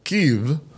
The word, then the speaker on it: Kyiv: In late January of this year, NPR decided to officially adapt on the air the pronunciation of Kyiv as the capital of the Ukraine to more closely resemble the way it's pronounced in Ukrainian. NPR had already changed the spelling from K-I-E-V in 2019 to K-Y-I-V to more closely resemble the original Ukrainian.